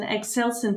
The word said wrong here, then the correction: Eccleston: Turner. Please do correct me if I'm wrong. – Yes, uh, um, historian Eccleston uh, Turner.